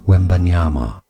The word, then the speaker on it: Wembanyama: Therefore, in English, it is said as Victor Wembanyama. – Okay, so let's all do it together. – All right, ready? – Because everybody needs to hear it. It's three syllables. Ready? Yeah. – Well, it's four, actually.